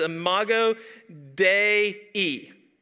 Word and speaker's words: imago Dei: She said this is imago Dei, that's how you say it, the image of God. Not imago Dei, imago Dei.